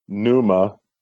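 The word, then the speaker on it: Pneuma: And I'm not, you know, a longtime Tool fan, but that song, Numa, which is a silent P at the beginning – and their song Invincible from their latest album this year.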